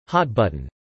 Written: hot-button

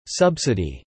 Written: subsidy

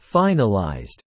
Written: finalized

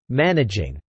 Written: managing

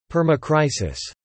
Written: permacrisis

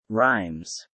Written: rhymes

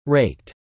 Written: raked

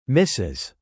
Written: misses

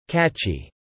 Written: catchy

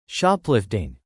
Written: shoplifting

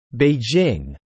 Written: Beijing

